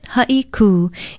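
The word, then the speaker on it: Haiku (Haʻikū): It's spelled H-A-Okina-I-K-U with a kahako or macron over the U. Pronounce it Haiku. It means a sharp break or to speak abruptly and is often confused with the Hawaiian term haiku for a type of poetry. Remember, it's Haiku.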